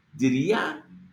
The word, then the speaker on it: Diría: Diría. There's an accent on the ea.